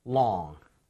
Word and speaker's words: long: L 발음할 때는 혀 끝이 윗니와 입천장 사이에 강하게 밀어줘야 됩니다. long, long.